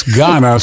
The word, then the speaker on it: Gaana: No, it's Ghana spelled G-A-A-N-A. – Spell it again. – Yeah, G-A-A-N-A.